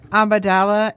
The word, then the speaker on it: Amidala: Okay, Queen Amidala, and there was some discussion about if she was at one point in one of the movies a princess.